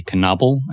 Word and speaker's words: Knable: It used to be Knable, and it was spelled K-N-A-B-E-L.